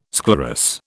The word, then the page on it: sclerous: British English